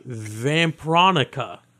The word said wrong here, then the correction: Vampironica: That's Vampironica. A vampire version of Veronica. – Vampironica? – Vampironica is the better way of pronouncing it. Yes.